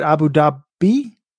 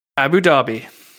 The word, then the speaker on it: Abu Dhabi: Abu Dhabi. – Abu Dhabi.